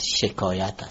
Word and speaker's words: شکایت: تلفظ درست شکایت، شکایت است.